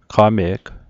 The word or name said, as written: qame'g